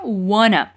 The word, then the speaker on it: wanna: A gente pode juntar want to e fica wanna.